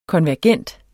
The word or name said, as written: konvergent